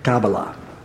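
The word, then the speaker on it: Kabala: Did I say that right? Kabbalah? Theo, I said it right. Okay, Kabbalah.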